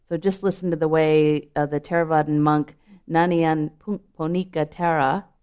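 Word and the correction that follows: Nyanaponika Thera: I'm not saying his name right, Nyana Ponika Thera, describes these Brahmaviharas.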